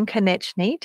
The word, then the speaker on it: Konechny: Your sister is Eva, and we've just had a spelling or a pronunciation lesson, Konechny. Did I get that right? – Perfect.